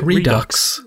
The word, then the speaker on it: Redux: Is pronounce it? Redu- Redux. Redux. Redux.